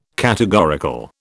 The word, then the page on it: categorical: British English